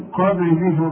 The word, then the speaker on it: قبل: وما محمد إلا رسول قد خلت من قبله الرسل. شوف من قبل البر اوعى تنسى القلقلة. شوف ما تقولش من قبل حتى تلاقي فيه صعوبة. شوف من قبل تلاقي فيه صعوبة في النطق.